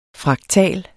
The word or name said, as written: fraktal